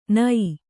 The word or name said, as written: ಣ